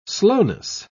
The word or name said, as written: slowness